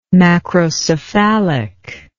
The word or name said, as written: macrocephalic